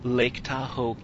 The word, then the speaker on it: Lake Tahoe: It sounds like that's kind of what you were studying at your, I'm sorry, let me say it again, Lake Tahoe camping retreat class? – Yep. – Did I say that properly? – You did.